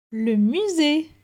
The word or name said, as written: Le musee